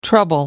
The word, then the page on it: trouble: American English